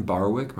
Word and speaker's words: Barwick: Yep, my name is Brandon Barwick. My last name is spelled B-A-R-W-I-C-K.